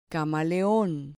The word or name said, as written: camaleon